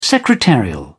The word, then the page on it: secretarial: British English